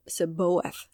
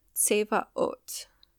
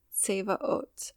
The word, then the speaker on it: Tsevaot: Um, Seb- Seboeth. I think that's how a lot of people pronounce it. Jehovah or Yahweh Seboeth. It is Yahweh Tsevaot. Tsevaot.